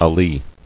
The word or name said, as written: Alee